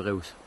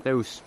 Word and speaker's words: Reus: Yo creo que hay que apostar por Marco Reus. – ¿Tú le llamas Reus? ¿Le estamos llamando Reus toda la vida? ¿Cómo se dice? – Reus.